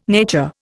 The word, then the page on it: nature: Indian